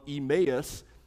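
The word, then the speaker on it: Emmaus: One helpful part of E-May-Us is it helps people pronounce our church's name correctly.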